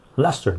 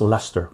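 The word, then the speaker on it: Leicester: Lester, Joe. The city's pronounced Lester.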